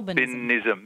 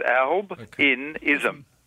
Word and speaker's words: albinism: It'll be... Albinism. So it's three syllables, isn't it? Albinism.